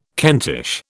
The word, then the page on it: Kentish: British English